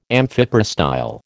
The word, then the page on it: amphiprostyle: American English